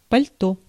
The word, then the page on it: pardessus: /paʁ.də.sy/